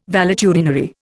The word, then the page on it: valetudinary: Indian